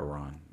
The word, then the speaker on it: Iran: I like Iran. – But... Iran. That's technically how it's pronounced, but, you know, what do you do?